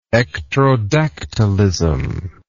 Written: ectrodactylism

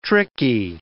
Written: tricky

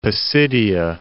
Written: Pisidia

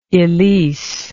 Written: elise

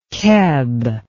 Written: keb

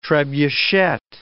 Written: trebuchet

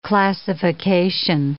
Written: classification

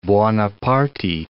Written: Buonaparte

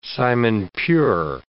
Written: simon-pure

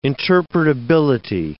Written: interpretability

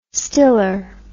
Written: stiller

